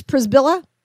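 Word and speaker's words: Przybyla: I looked it up, and I think I'm right about the pronunciation. I don't want to screw this up. Heidi, I believe it's Prisbilla.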